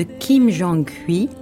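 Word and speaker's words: Kim Jong-hui (김정희): Nous sommes aujourd'hui en compagnie de Christine Jordis, qui va nous emmener en Corée sur les traces de Kim Jong-hui. Je ne sais pas si je prononce bien. – Très peintre. Bien, c'est ça.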